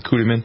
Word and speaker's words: Kutiman: K-U-T-I-M-A-N, Kuterman.